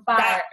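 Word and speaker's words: Fyre: All right, so the Back to the Fry Festival. – The Fire.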